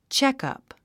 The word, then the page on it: checkup: tʃékʌ̀p; チェクアプ